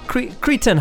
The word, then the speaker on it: Cretins: Anyway, before that we heard from, I pronounce them the Cretans. How do you say it? – Cretan. – Cretan, stupid, dumb it means.